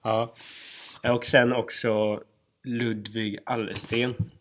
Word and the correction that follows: Arlesten: Som är sjuk, Arlesten. – Ja, bra uttalat.